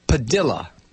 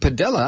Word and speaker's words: Padilla: our family name is pronounced Padilla, not Padilla.